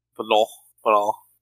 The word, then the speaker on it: Manon: Manon. It's like definitely a French pronunciation, the kind of nasally and sound.